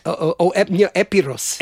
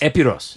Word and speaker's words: Epirus: I'm probably mispronouncing it. Epirus, this place in northwestern Greece? – Epiros. – Epiros. – Yeah.